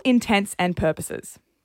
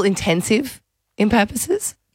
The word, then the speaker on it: intents and purposes: For all intents and purposes. – Is it all intenses, all intensive in purposes? – That's no. The wrong way to say it.